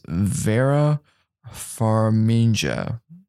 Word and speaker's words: Vera Farmiga: Her name is Vera Farmiga.